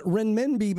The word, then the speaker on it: renminbi: I'm probably saying that wrong. But renminbi apparently is their digital, but the digital version, an all-digital version of its paper currency. – Renminbi. – Thank you. Renminbi? Is that what it is? – Yeah, renminbi.